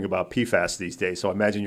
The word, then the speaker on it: PFAS: PFAS is a good way to pronounce it.